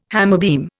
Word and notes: hammerbeam: Indian

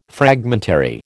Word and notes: fragmentary: American English

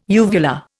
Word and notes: uvula: Indian

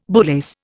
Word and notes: bullace: Indian

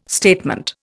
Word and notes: statement: Indian

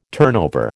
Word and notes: turnover: American English

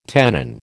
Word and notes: tenon: American English

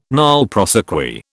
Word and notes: nolle prosequi: British English

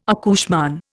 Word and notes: accouchement: Indian

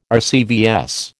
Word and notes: RCVS: American English

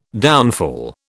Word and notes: downfall: British English

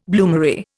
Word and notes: bloomery: Indian

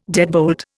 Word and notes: deadbolt: Indian

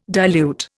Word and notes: dilute: Indian

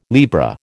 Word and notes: Libra: American English

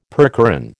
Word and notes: percheron: American English

